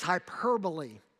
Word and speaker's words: hyperbole: Is that hyperbole? The word there is hyperbole.